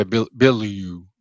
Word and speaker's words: Bilyeu: I think Tom Bilyeu, I'm sure I'm pronouncing Tom's last name. – Yeah, Tom Bilyeu from Impact Theory. – Yeah. Yeah. Bilyeu. Yeah, see, sorry. Too many syllables there for me.